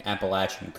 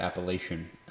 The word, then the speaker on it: Appalachian: I also want to thank you for pronouncing Appalachian correctly, unlike those heathens that say it Appalachian.